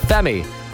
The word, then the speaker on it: FEMI: And you know our playlist is jam-packed with pure Irish talent at the moment, including this man whose name is spelt F3Me, but it's pronounced Femi.